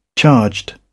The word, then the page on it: charged: British English